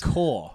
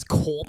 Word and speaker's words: Corps: I think Core. I don't want to get into more pronunciation. – I know it's Core. It sounds so much more fun to say it as Corps.